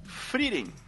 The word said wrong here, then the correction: Frieren: É, ou, que nem eu pronunciei errado, Frieren.